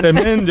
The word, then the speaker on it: msemen: Msemen, yo que sé, en otros lugares se conoce como ergaif. Es una palabra complicada, decía, es M-S-E-M-E-N. Msemen, msemen, ¿vale? Y se pronuncia como lo ha hecho Hakima.